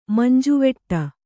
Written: ಮಂಜುವೆಟ್ಟು

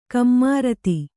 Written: ಕುಮಾರಿತಿ

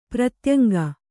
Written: ಪ್ರತ್ಯಂಗ

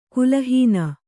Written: ಕಲಾಹೀನೆ

